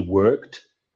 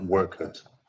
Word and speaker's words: worked: You have to pronounce the T at the end. Watch, you say watched, and strong T at the end. Stop, stopped. And finish, finished. So remember, no extra syllables. No, finish it, not stop it, not watch it, not work it.